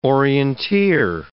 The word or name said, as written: orienteer